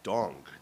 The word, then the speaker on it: Dong: And then his last name is Dong, D-O-N-G, like ding dong.